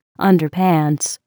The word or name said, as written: underpants